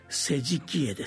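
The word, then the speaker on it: 施食会: 並行してこの時期に曹洞宗のお寺で多く行われる行事が世辞帰依です。食を施す会と書いて世辞帰依と読みます。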